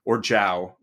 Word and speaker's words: Zhao: But he's able to convey that same emotion also just with a look, and he and Ken Leong continue to be Zhao, which the different characters are pronouncing it different ways, but I guess I'll mostly say Zhao because that's how the character himself is saying his name.